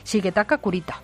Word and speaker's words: Shigetaka Kurita: Shigetaka Kurita.